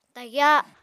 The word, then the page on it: tagyá: Apatani